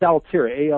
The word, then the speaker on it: Altria: It's Altria, Altira, Altria Group, yeah.